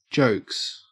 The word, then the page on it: jokes: Australian English